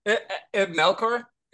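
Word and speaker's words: Ib Melchior: I'm going to ask you to pronounce the name. – Sure. Ibn Melkor is how I say it.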